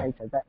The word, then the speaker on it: Taito: Taito.